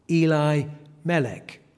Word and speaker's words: Elimelech: We often hear it pronounced as Elimelech, but I have been pronouncing it, which is more how it would be in the Hebrew, Elimelech.